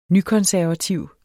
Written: nykonservativ

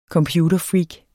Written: computerfreak

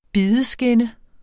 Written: bideskinne

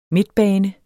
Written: midtbane

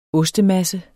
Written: ostemasse

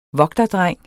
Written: vogterdreng

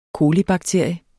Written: colibakterie

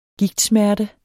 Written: gigtsmerte

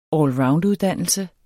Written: allrounduddannelse

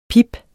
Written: pip

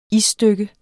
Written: isstykke

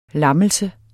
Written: LAMMELSER

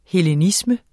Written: hellenisme

